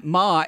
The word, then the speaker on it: Maher: My name is Matt Maher. It doesn't rhyme with hard.